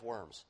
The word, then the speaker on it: Worms: If you see it spelled out, by the way, in history books, it makes you laugh because it looks like diet of worms. But it's really diet of Worms is how you pronounce that.